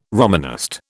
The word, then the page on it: Romanist: British English